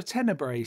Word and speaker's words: tenebrae: There's a thing called a tenebrae service, isn't it, which I might pronounce wrongly, which I no, that's right.